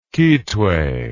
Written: kitwe

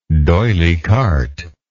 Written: doyly carte

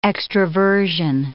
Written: extraversion

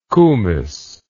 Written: kumiss

